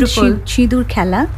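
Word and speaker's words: Shidur khela (সিঁদুর খেলা): The red sindoor khela. Sindoor khela, am I right in saying so? I don't know the Bengali pronunciation. – It's called Shidur khela.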